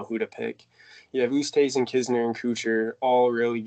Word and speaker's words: Oosthuizen: You have Lewis Oosthuizen. Did I say that right? – Oosthuizen.